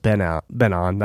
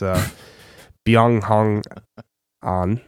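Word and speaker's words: Byeong Hun An: It's not just Ben on. That's his American name. It's Byung Hong on.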